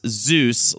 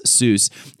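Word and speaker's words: Soos: Alex said, the handyman Zeus, often mistaken for being pronounced Zeus, like the Greek god, it's S-O-O-S, Zeus, is 100% inspired by my friend named Jesus from college, who was this just friendly, lovable, and deeply strange human being.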